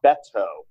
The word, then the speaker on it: Beto: You know, just monitoring Twitter, we're at the point now where there are so many candidates that they're actually putting on Twitter the proper way to pronounce their names. It's Kirsten. It's Beto.